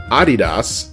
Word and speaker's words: Adidas: But in the late 1960s, Adidas, or Adidas as the rest of the world correctly pronounces it, they decided to enter the basketball shoe game.